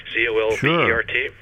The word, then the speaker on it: Colbert: So let me give you a name, and I'm horrible pronouncing foreign languages, but the Jean Baptiste Colbert, C O L B E R T, Colbert.